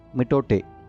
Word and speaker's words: mitote: The word is pronounced mitote.